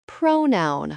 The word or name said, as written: pronoun